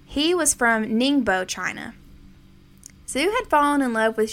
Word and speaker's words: Zhu: but it is actually spelled H-A-I-Y-A-N-G. Last name is spelled Z-H-U. I'm also going to be referring to him by his last name, and I'm going to be pronouncing it Zhu.